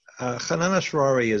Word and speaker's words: Hanan Ashrawi: Is Anan Ashrawi a PFLP supporter? And feel free to correct me on the pronunciation. – Hanan Ashrawi,